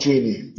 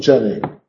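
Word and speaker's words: Jeanie: So why do I call this woman Jenny? Because it rhymes with many in the poem. So we know that she wanted to, it can be pronounced either way, we know that she wanted to rhyme it with Jenny.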